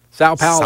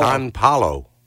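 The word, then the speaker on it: São Paulo: But it's S-A-O. – I thought it was San. – No. Sao Paulo. – San Paulo. – I mean, that's how it's spelled. Said. Mm. How would you say it? – I've always said San Paulo. – Well, that'd be wrong. There's no N there.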